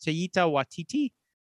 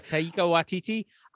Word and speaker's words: Taika Waititi: It took me a long time, but I believe it's pronounced Taika Waititi. – Okay. – Taika Waititi.